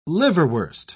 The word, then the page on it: liverwurst: British English